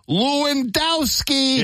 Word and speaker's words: Lewandowski: So did Chicago Fire international soccer superstar Robert Lewandowski, or as we say in Chicago, I don't care how they say it in Poland, Lewandowski. – Yeah. – The man's name is Lewandowski. Don't try to tell me otherwise.